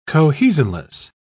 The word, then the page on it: cohesionless: British English